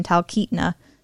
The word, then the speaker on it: Talkeetna: The due date for this first application for Alaska Floats and Skis rating up in Talkeetna. Did I pronounce that right? – You did. – Talkeetna, good for me.